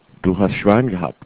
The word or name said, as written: Schwein gehabt